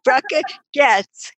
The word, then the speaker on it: Braca Goetz: Did I say that right? – Almost. Braca Goetz. Goetz, yeah. – Yes, Braca Goetz. I put an A instead of an O. Braca Goetz. G-E-T-S in my mind.